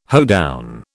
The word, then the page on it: hoedown: British English